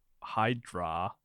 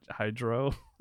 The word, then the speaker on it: Hydro: I mean, if you were to separate the words, you don't pronounce it Hydra, right? – No, like it's Hydro.